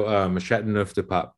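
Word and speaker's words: Châteauneuf-du-Pape: So Châteauneuf du Pape is the pronunciation.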